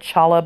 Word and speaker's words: challah: I mean, it's spelled like challah, but I guess you pronounce it challah.